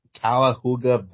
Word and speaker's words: Cuyahoga: Cuyahoga Valley. That's how you say it.